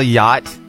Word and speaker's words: yacht: It's Y-A-C-H-T. It's like yacht. All right. But the C-H is silent. Yacht.